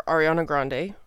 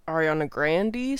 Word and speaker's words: Ariana Grande: Her name is pronounced Ariana Grande sometimes when she says it. I'm going to say Ariana Grande the whole time because that's the consensus culturally. And when you Google how to pronounce it, the Google pronunciation is Ariana Grande. So I think that that is what is acceptable, even though it directly contradicts what she actually says.